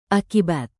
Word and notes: akibat: indonesian

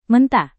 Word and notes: mentah: Indonesian

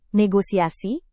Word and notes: negosiasi: Indonesian

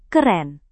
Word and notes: keren: indonesian

